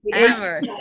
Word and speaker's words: Amherst: Am I pronouncing that one right? – Amherst. – Okay. I'm from Long Island, so well, I don't know all the towns up there. I'm sorry. – Lots of people in Massachusetts don't pronounce it. Oh, okay.